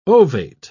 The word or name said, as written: ovate